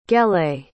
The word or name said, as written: gelée